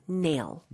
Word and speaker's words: nail: 아, 그리고 우리나라 발음하고 살짝 달라요. 내일보다는 이름절로 nail.